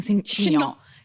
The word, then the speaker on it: chignon: Can I wear a wig or like a chignon? – 100%. Yes. Only if you keep saying chignon. – Is that how you say it? – Chignon. – Chignon. – Indeed.